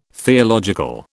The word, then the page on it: theological: British English